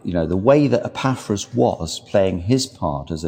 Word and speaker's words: Epaphras: E-P-A... – I can't spell it. – E-P-A-P-H-R-A-S.